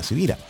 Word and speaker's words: Sivira: Mi nombre es Carlos Javier Sivira y como estoy claro que mi apellido no es tan común, se escribe con S de semana y con B de Victoria, Sivira.